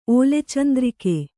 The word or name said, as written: ಓಲೆಚಂದ್ರಿಕೆ